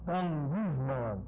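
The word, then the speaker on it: Gongusman (γογγυσμῶν): These two G's, when they come together, they're pronounced ng. Gongusman.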